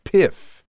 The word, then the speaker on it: PYF: P-Y-F, pronounced PIF.